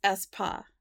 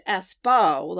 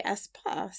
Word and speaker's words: Espoo: And then I went and did additional research, and it's like, yeah, it's Espoo. Yeah. So it's cool. Because Jackie, I think, was pronouncing it Espoo, like Expo. But it is actually Espoo.